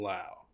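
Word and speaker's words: Lowe: There should only be one pronunciation of this. This should, like, Rob Manfred needs to get on this. All Lows are pronounced the same way. – Nate Low. So it's Brandon Lau. – Brandon Lau. Yeah, there you go.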